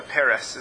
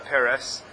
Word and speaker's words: peres: Yeah, the second bird is called a peres, is the way it's pronounced in Torah, a peres.